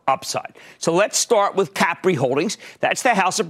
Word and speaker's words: Capri: Capri, which, by the way, that's how they pronounce it over there.